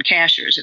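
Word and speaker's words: Cashiers: And that's the name of a mountaintop town, Cachers. It's spelled just like a cashier at the grocery store.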